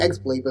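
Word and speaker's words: X-Blade (χ-blade): Well, it looks like the X-Blade, but pronounced key, like key.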